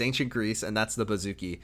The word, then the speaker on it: bouzouki: called the bouzouki, I think it's pronounced.